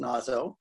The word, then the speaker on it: Naso: I assume it's pronounced Naso. Though I think W.S. Gilbert rhymes it with say so in one of his lyrics in Iolanthe.